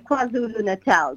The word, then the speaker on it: KwaZulu-Natal: KwaZulu-Natal.